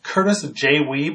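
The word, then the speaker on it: Kurtis J. Wiebe: Okay, we're here with Curtis J. Weeb.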